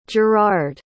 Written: jerard

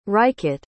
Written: Rajkot

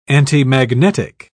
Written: antimagnetic

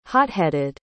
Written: hot-headed

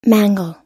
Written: mangel

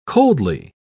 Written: coldly

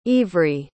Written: Ivri

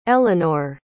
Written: eleanore

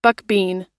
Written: buckbean

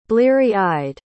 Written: bleary-eyed

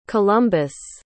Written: columbus

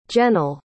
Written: jennyl

